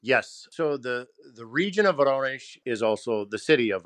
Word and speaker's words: Voronezh: So I guess from a geographic standpoint, am I correct that and I'll say it wrong, Voronezh, how did you say the region where you were? – Voronezh. – There you go.